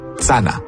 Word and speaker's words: channa: En Abenaki, ça s'écrit « c-h-a-n-n-a », mais il n'y a pas le son « ch ». À la place, on utilise le son « ts ». Et donc, ça donne « sana ».